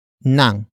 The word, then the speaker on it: nang: After that, we have nang, which is spelled as ng.